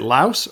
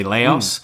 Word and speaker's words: Laos: Is it Laos or Lao when you say it? – That's always the classic question. Yes, I mean, it should be Lao, but of course, I think some North Americans say Laos.